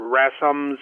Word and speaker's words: Rassum: One, a full and independent review of Professor Rasmus, is how you pronounce his name, I believe, R-A-S-S-U-M,